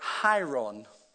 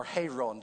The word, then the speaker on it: hieron: The second is the word Hieron, or Hieron, depending on how you pronounce it.